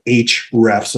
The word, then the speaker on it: Ahrefs: There's another one that's called AHREFS. So HREFS is how that's pronounced.